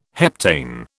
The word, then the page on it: heptane: British English